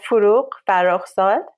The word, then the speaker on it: Forugh Farrokhzad: And then you turned to the life of, now I'm going to butcher her name, and I really don't want you, Farouk Farrokhzad? – Not bad. So Farouk Farrokhzad, and she was known, she's sort of, you know, like a queen.